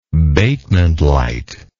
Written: Batement light

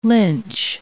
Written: lynch

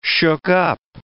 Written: shook-up

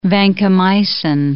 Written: vancomycin

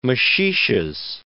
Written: maxixes

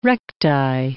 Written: recti

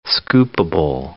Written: scoopable

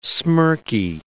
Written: smirky

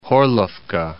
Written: Horlivka

